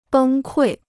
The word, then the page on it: 崩溃: bēng kuì